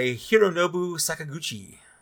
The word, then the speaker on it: Hironobu Sakaguchi: The first game was developed by Hironobu Sakaguchi.